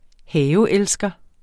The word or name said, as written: haveelsker